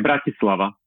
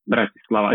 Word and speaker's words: Bratislava: Now, am I pronouncing Bratislava correctly? – Yeah, Bratislava, but it's Bratislava. – Fine when you... – Okay. – Bratislava. It's like brat, you know, like if someone's behaving like a brat.